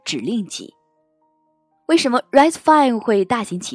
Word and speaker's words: RISC-V: RISC-V 读作 RISC-V，是二零一七年图灵奖得主 David Patterson 发起并倡导的开放指令集架构。